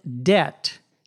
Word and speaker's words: debt: To be in debt, D-E-B-T, notice the B is silent, means to owe money to other people or to a bank.